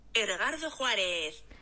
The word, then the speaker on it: Edgardo Juarez: 哎，等一下，因为他是墨西哥文，所以刚刚我还特地去找了读音。但我现在又忘记了，我来播一下他的读音啊。 Edgardo Juarez 啊，这个人叫做 Edgardo Juarez，但是呢，更多人称他为 Edgardo Preso。